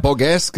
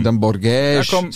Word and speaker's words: Borges: Ja, ich habe Borges gesagt, weil ich habe es ein bisschen spanischer ausgesprochen, aber man klar, er ist natürlich Portugiese, dann Borges, Sagres.